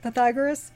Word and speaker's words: Pythagoras: Pythagoras, so I found out how you pronounce it, Pythagoras.